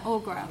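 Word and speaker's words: Aughra: When he meets, is it Algra? Is that how you pronounce her name? – I think so. Algra, Algra. – Algra. – Augra. – Augra. That might sound right.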